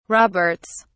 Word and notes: roberts: British English

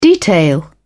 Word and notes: detail: British English

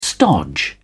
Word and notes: stodge: British English

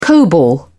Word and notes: cobol: British English

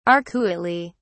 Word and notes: arcuately: British English

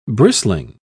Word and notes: bristling: British English

